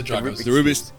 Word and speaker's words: Rubik: Well, that was where the Rubik's joined us. – So, yeah. – All right, the Rubik's.